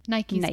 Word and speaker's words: Nike: How do you say it? Nike or Nike? – Oh my God, never heard Nike in my life. It's definitely really? Nike.